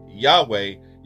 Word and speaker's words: Yahweh: It is to be pronounced with you inhaling, and all, upon exhale, the word or the letter or word or phrase way is to be exhaled upon pronunciation. So when it's pronounced, when you say Yahweh, you should be saying it as if you're breathing in inward, on the first part and exhaling on the latter.